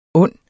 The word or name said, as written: ond